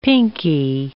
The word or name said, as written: pinky